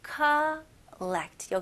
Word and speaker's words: collect: 여기서는 오 발음이지만 호 이런 느낌 아니고 collect. 여기는 거의 A 느낌으로 하셔도 돼요. 예를 들어서 누구한테 call 할 때 collect. collect. A하고 U 사이인 것 같아요.